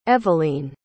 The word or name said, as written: evaline